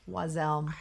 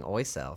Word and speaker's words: Oiselle: Is that how you pronounce it? – Take them off ever. – Wazelle? – Wazelle. – I've been saying Oiselle for the longest time. – Oiselle? – It looks like Oiselle.